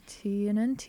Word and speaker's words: Tiernan: Is that how you pronounce it? – Yeah, Tiernan.